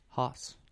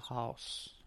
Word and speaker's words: Haus: But in German, it's Haus. – Oh, that's close. – Haus.